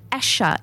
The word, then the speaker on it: Asa: Asha is the first up, and it's spelled A-S-A.